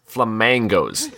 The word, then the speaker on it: Flamangos: Alex, the restaurant is pronounced Flamangos, like flam, then mangoes.